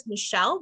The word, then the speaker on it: Michelle: So you can simply follow my name is Michelle, that's spelled with two L's, and my last name is Soltman, S like Sam, Oltman.